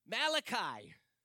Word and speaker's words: Malachi: Malachi, to say it right, if you were wondering.